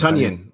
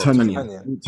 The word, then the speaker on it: Tonyan: It's Tonyan. You got to pronounce it like Funyan. – So Tonyan.